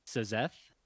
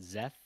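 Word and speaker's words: Szeth: But are you telling me that they just pronounce that Zeth? – Yes. – Zeth. – They pronounce it Zeth as in a Z and then Eth. They just, Eth, yeah. – The S is silent is in the audiobook.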